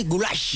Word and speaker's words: goulash: El goulash es pronunciado así, goulash.